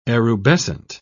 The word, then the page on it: erubescent: British English